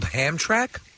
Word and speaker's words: Hamtramck: It's called Hamtramck? – Hamtramck. It's spelled Ham, H-A-M-T-R-A-M-C-K.